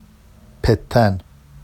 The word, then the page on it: pettan: Vafsi